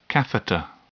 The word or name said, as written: catheter